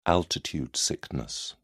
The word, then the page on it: altitude sickness: British English